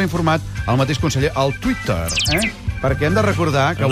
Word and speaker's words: Twitter: No, avui el Twitter, que si ho pronunciem en català oriental i fem les vocals neutres, hem de dir Twitter i no Twitter.